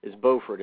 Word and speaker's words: Beaufort: Now, there's a Beaufort in North Carolina and a Beaufort in South Carolina, and they're spelled the same, but they're pronounced differently. But this one is Beaufort.